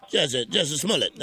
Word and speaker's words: Jussie Smollett: Jussie Smollett, that's how they say it. Jussie Smollett.